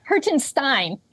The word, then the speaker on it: Hertenstein: Did I say that right? – It's Hertenstein. – Hertenstein, Steen, Stein.